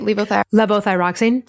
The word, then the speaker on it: Levothyroxine: Levothyroxine.